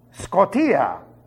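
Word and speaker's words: Skotia (σκοτία): I said, the way it should be pronounced. Skotia. S-K-O-T-I-A. I-A. And the skotia occurs in their dianoia. D-I-A-N-O-I-A.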